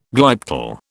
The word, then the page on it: glyptal: British English